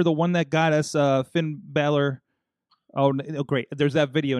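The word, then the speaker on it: Finn Bálor: Finn Balor.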